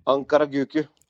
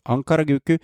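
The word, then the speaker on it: Ankaragücü: uttaler du dette, her, gutter? – Ankaraguku. – Ankaraguku.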